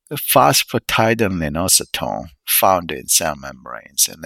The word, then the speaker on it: phosphatidylinositol: Okay, phosphatidylinositol. All right, let me double check the pronunciation of the word. All right, that completely threw me off guard. Okay. Okay. Phosphatidylinositol.